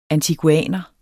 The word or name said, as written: antiguaner